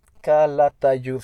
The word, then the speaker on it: Calatayud: Sí, es que estoy, no veo, Calatayud,